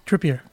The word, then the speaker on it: Trippier: ¿Cuál es la pronunciación correcta de nuestro lateral derecho? ¿Tripper o...? – Trippier. Trippier.